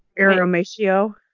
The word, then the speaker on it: irrumatio: It's I-R-R-U-M-A-T-I-O. Iromatio. – Spell it again. – I-R-R-U-M-A-T-I-O.